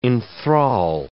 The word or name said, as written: enthrall